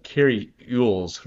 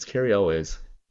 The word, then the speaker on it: Cary Elwes: It's Cary Elwes. I took out syllables for no reason. And just try to give him a new last name. – It's a very complicated name. – Not really. It's only two syllables.